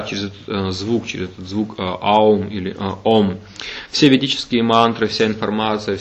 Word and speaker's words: ом: И когда человек произносит этот звук, то а звучит где-то, так сказать, в животе внизу, у это уже ближе сердца, горло, м это звук, который звучит в голове, в высших структурах, так правильно произносится этот звук ом.